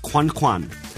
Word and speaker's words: coin coin: In France, they write it down as coin coin, which is pronounced quan quan.